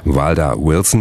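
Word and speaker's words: Valda Wilson: Dazu im Studio sind zwei Akteure, die beide Hauptrollen spielen, nämlich die Opernsängerin Walda Wilson, ich hoffe, ich habe es richtig ausgesprochen. – Absolut richtig, okay.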